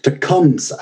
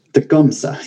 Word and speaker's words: Tecumseh: For example, the city of Tecumseh, and it's it's very overpronounced, uh, and uh, the gumsa yeah it's it's it's interesting like it,